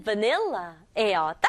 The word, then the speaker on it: vanilla: 첫 번째 맛은 vanilla예요. 딱 봐도 친구들 무슨 바닐라 맛인 거 알겠죠? 우리말로는 바닐라라고 쓰지만 영어는 vanilla. vanilla 이렇게 발음해주면 좋겠어요. V-A가 V 소리 나고요. N-I-L, nil 그리고 LA가 L 붙여서 vanilla.